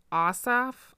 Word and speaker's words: Ossoff: But Reverend Raphael Warnock and John Ossoff, that's how you say it, will be Georgia's first Black and first Jewish senators in Georgia's history.